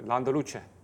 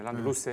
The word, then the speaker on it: Landaluce: Burada anlattım e, Landaluce. Ha gördüm. – E, Landaluce evet. – pardon öyle okunuyordu.